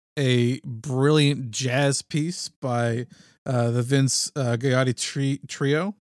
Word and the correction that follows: Vince Guaraldi: I think it's Vince Guaraldi. I think it's pronounced.